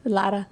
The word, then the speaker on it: Lara: But your last name's L-A-R-A. – Yeah. – So it's Lara. – Lara. – Lara. With that rolling R. – Roll the R.